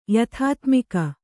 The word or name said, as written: ಯಥಾತ್ಮಿಕ